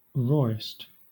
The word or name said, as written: rawest